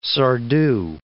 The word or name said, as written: Sardou